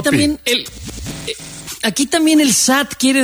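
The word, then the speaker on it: sat (七): No, algo muy, muy cercano, porque siete en chino, el carácter chino se pronuncia sat en cantonés.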